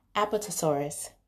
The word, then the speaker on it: Apatosaurus: Apatosaurus. This is how you say it. Apatosaurus.